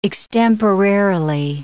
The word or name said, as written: extemporarily